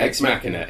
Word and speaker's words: Ex Machina: Is it Ex Machina? – Yeah, Ex Machina. It's from the Latin Deus Ex Machina. God from the Machine.